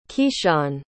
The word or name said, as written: keshawn